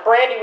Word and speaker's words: Brandi: Because it's pronounced Brandy with an I, but it's spelled like it's underscore BRANDWITHANEYE underscore.